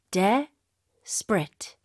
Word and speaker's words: desperate: yeah 뭔가 승진할 거에 대해서 굉장히 뭐 절박하다 이런 식으로 쓸수 있다든가 그리고 이거는 원어민들은 주로 이음절로 이렇게 발음해요. 그래서 desperate, desperate.